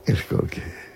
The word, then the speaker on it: Skolsky: ¿Cómo se pronunciaría en polaco Skolsky? – Skolsky. Como tú lo has dicho.